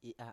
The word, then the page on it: i7a: Biak